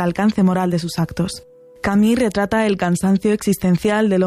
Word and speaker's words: Camus: Narrado por nuestros compañeros de Un libro, una hora, inaugura la primera novela del escritor argelino francés Albert Camus, o Camus, como se pronuncia en su idioma natal.